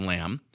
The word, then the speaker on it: lame: Now, I prefer to use what's called a lamb, and it's spelled lame, but it's pronounced lamb.